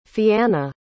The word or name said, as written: fianna